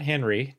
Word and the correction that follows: Henri: David, because it's spelled with an I. – Oh, Henri? – Charles Henri.